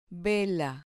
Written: vela

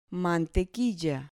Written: mantequilla

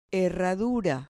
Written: herradura

